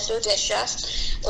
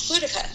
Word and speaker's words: Boudica: Now, for years I've said her name incorrectly. I've said Boudicca or Boudicca, and some people might consider that a proper pronunciation, but in my reading and my research, I have actually found that the proper pronunciation of it is Boudica. And the reason for that is that it's not English. It is, it is Iceni.